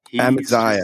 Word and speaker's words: Amaziah: Amaziah? – Amaziah.